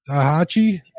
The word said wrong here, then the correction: Daiichi: Did I say that right? Daiichi. – Daiichi.